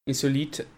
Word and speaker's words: Insolite: The Insolite? Insolite. Yeah, so it's sort of like the French meaning sort of strange.